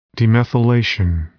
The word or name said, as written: demethylation